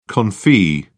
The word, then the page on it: confit: British English